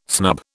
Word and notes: snub: British English

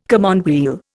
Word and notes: commonweal: Indian

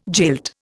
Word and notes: jilt: Indian

